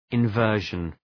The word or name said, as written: inversion